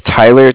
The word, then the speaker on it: Tyler: My name is Tyler, T-Y-L-E-R, and okay. I'm calling with Cardiff. It's spelled C-A-R-D-I-F-F, as in Frank Frank.